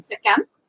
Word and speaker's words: Sekhem: Yeah, we call it Sekhem. In the Isis lineage, it's spelled, oh my goodness, S-E-K-H-E-M, and that okay.